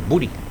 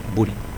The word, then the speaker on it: Buri: Se escribe Buori, ¿no? Pero en el ámbito artístico, a don Ricardo se lo conocía como Buri, con B larga, U-R-I, Buri.